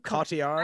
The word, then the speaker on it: Cotillard: Cotillard.